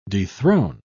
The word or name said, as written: dethrone